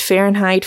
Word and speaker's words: Fahrenheit: So just going on record, it should be pronounced Fahrenheit 451.